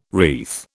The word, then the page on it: wreath: British English